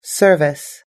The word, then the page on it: service: American English